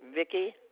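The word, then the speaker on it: Vicki: Vicky Brown. – And Vicky is spelled V-I-C-K-Y? – I. Yes, ma'am. – So it's V-I-C-K-I.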